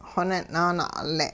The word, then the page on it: xonin'-naana'ule': hupa